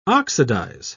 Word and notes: oxidise: British English

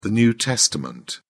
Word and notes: new testament: British English

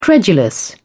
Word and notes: credulous: British English

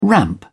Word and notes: ramp: British English

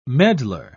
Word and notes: medlar: British English

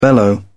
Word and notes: bellow: British English

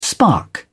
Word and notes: spark: British English

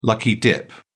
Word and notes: lucky dip: British English

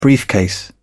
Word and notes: briefcase: British English

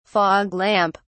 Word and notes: fog lamp: British English

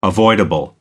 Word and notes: avoidable: American English

